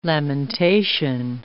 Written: lamentation

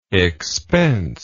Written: expenseless

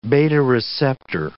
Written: beta-receptor

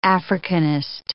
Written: Africanist

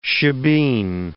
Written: shebeen

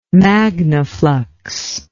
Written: Magnaflux

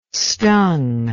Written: stung

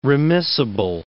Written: remissible

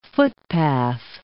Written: footpath

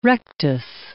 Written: rectus

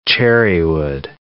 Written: cherrywood

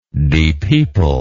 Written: depeople

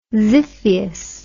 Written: xiphias

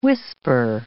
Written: whisper

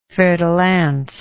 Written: fer-de-lance

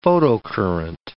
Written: photocurrent

